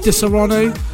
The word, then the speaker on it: Disaronno: This one in the background is by a chap called Disaronno, pretty much like the drink is how it's spelled, Disaronno.